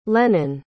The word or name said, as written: lenon